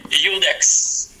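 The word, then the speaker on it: Iudex: Ludex, track 9, if I said that right. – It's Udex. – Oh, Udex, excuse me. Udex. – It's Udex. Yeah, it's Latin for judge.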